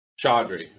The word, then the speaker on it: Chaudhry: Chaudhry.